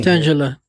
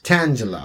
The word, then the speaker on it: Tangela: Tangela. – Tangela? – Yes. – Tangela.